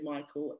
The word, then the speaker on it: Michael: I'm going to confess this to my audience, that I have been calling you Macau for the last month or so, but then realize it's the Dutch pronunciation of Michael.